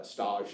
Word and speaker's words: stage: It's spelled stage, but it's, you know, French. – Okay. – So stage is just like an unpaid working interview.